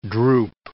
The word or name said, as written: droop